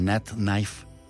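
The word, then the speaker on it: Nath Nife: Ella está como N-A-T-H, N-I-F-E, o sea, Nat Knife. Nat Knife. Sí lo dije bien, ¿verdad?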